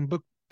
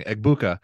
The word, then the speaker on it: Egbuka: They have this other guy, Emeka Egbuka. Oh, God, I knew I was going to butcher it. I was practicing. Egbuka, 49 receptions, 794 yards, 7 touchdowns.